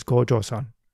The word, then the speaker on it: Gojoseon: To distinguish this first state, we put the character for ancient or old, pronounced go in modern Korean, in front. So, put together, we refer to this state, Joseon, as Gojoseon.